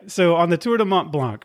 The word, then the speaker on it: Mont Blanc: And I'm going to give it the English pronunciation for the moment. I can try it. Tour du Mont Blanc en français. – Sure. – Mais aujourd'hui en anglais.